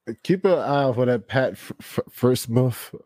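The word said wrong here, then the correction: Freiermuth: I don't know how to say his name. – Firemuth. – Yeah, Firemuth.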